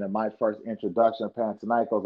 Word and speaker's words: Panathinaikos: And I've been saying it wrong for 20 years. What's the name? – Panathinaikos.